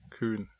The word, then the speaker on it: Khun: Kun, yeah. – I'm probably pronouncing it wrong. – Kun, yeah. K-H-U-N. Kun. Okay, cool.